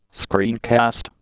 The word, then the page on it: screencast: Canadian English